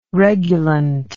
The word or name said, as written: regulant